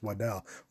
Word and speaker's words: Waddle: Although he said he wanted his name to be pronounced Waddell.